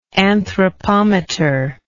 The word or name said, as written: anthropometer